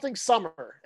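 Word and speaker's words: Sommer: Summer is his name.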